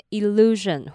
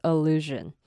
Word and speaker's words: illusion: 最后一个单字呢是 illusion，那其实有两个版本，有些人会把前面那个 i 发成 e，那也有人会把它发成所以你可以念成 illusion 或是 illusion。那要注意一下后面那个 s-i-o-n，它不要念成省，它是有点摩擦音的热的声音，所以它是念成 illusion。